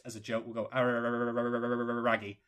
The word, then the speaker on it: Araragi: The thing is, the scene I'm referring to, by the way, to clarify, in Monogatari is, of course, the one between Araragi and Senjigahara.